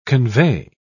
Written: convey

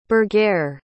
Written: burghere

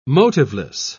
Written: motiveless

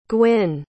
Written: Gwynne

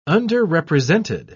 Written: underrepresented

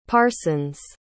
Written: parsons

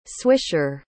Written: swisher

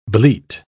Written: bleat

